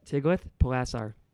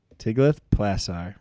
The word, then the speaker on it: Tiglath-Pileser: Tiglath-Pileser. – Tiglath-Pileser. Man, I've been saying it wrong for so long.